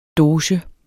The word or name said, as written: doge